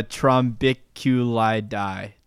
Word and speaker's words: Trombiculidae: Weird, but just off the top of my head, it does say Trombiculi. I'm trying to remember it. It'll come to me if I mispronounce it a lot of times in a row. It's a Trombiculidae.